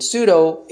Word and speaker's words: pseudo: And so the word false prophet, or the phrase false prophet, was translated from the word pseudo prophetess, pseudo, P-S-E-U-D-O, which I pronounce pseudo, but I think, you know, we would pronounce it pseudo in English.